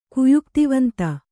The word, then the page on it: ಕುಯುಕ್ತಿವಂತ: kannada